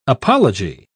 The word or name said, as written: apology